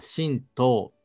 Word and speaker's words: 神道: まあ、前回、申し述べたこととかなり重複するところが出てくるとは思いますけれども、まあ、神道というのは、まあ、神の道と書いて、まあ、神道と読みますけれども、まあ、日日本にまあいらっしゃる八百万の神様をお祭りするまあ宗教になります。